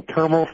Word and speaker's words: Termal: The Baseball Reference suggested it rhymes with thermal. – Thermal. Termal.